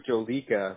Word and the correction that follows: Jolokia: but I think it's Jolokia.